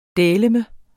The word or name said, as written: dæleme